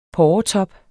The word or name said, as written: porretop